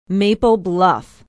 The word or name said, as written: maple bluff